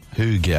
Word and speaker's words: hygge: So, hygge, I was talking about.